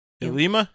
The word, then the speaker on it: Ilima: I don't know why I thought there was still going to be another encounter with Ilium? – Ilima? – Ilima? Sorry.